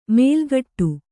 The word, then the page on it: ಮೇಲ್ಗಟ್ಟು: kannada